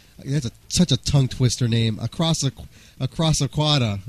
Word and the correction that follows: Coda: right? – Did I say that right? Coda.